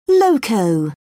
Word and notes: loco: British English

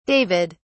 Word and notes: david: British English

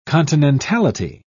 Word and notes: continentality: British English

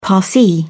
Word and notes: parsi: British English

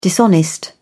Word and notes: dishonest: British English

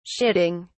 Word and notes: shitting: British English

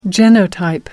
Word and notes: genotype: American English

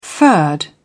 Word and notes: furred: British English

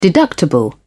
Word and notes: deductible: British English

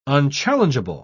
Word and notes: unchallengeable: British English

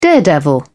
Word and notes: daredevil: British English

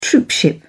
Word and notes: troopship: British English

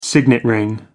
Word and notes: signet ring: American English

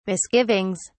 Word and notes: misgivings: British English

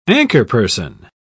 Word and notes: anchorperson: British English

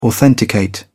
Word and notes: authenticate: British English

